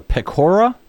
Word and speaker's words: Pecora: Your publisher, Random House, notes that in the book you call you close with a call for a version of the Pecora Commission. – Yep. – Is that how you say it? – Yeah.